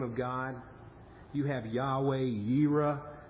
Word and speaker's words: Yahweh Yireh: You may have heard Yahweh most often pronounced as Jehovah, just a different way of pronouncing the same Hebrew word. Jehovah, Yahweh, Jireh. That's how we, Jehovah Jireh, Yahweh Yireh is how it's said in Hebrew.